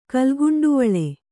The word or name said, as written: ಕಲ್ಗುಂಡುವಳೆ